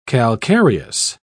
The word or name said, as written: calcareous